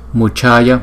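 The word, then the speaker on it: mucaya: Buddhaya, Buddhaya, Vibuddhaya, Vibuddhaya, Muchaya, Muchaya, Vimuchaya, Vimuchaya, thấy không? Chữ C và chữ H chúng ta nhấn hơi mạnh một chút.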